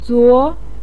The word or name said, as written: zuo2